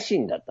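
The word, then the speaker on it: 四十: 麺屋シーンだったんですね、天白になった時。 – はい、しかも、あの、漢字でね。 – そう、漢字で 40 って書いて、シーンって読むっていう、はい、この、まさかのポケベル読みという。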